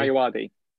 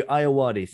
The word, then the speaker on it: Ayoade: Ayoade. – Thank you, Ayoade.